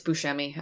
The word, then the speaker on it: Buscemi: Nope, it's Buscemi. I don't care. He got his name wrong.